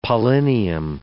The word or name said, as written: pollinium